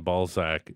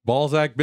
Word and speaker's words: Balzac: The Z in Balzac is actually pronounced as an S.